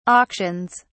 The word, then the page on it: auctions: British English